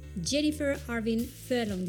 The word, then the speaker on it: Jennifer Arvin Furlong: Her name is Jennifer Arvin Furlong.